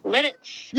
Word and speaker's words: Lititz: Lititz.